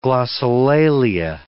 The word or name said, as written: glossolalia